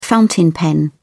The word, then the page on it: fountain pen: British English